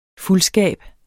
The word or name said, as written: fuldskab